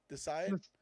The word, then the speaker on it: Desaad: Like, even the visual of how do you pronounce it? Desaad. – Desaad.